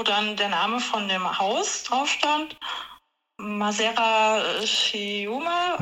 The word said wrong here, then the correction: Masseria: Masseria, genau, Masseria Schiuma. Masseria, ja.